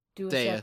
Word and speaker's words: Deus: He's a deuce. He's a. Or I always say this wrong. It's like. – Deus. – He's just there to, like. – Yep. – Deus, that's the one.